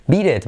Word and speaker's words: beat it: Entonces tenemos beat, que se escribe con e pero suena con i, y it, que se escribe con i pero suena como e. Beat it, ahí se ve claramente, beat it, beat it, beat it, ¿ok?